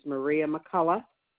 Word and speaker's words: Maria McCullough: My last name and my first name is Maria McCullough.